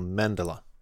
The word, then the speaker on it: mandala: And it says emphasis on the first syllable, so mandala.